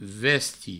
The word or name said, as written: vesti